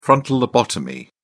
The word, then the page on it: frontal lobotomy: British English